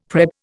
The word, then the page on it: prep: Indian